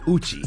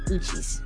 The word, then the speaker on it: Uchis: Yeah, the S is not supposed to really be pronounced. – Really? – But some people do say, you know, Uchis. – Okay. – But it's Uchi.